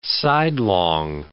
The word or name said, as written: sidelong